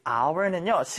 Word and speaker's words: hour: 우리 hour는요, 시간 단위에서 시를 얘기합니다. 일단 한번 적어볼까요? H-O-U-R. 자, 그냥 넘어가기 전에 우리 hour에서 우리 H 하면은 왠지 hour라고 발음해야 될 것 같은데 이때 이 H는요 발음하지 않는 묵음입니다.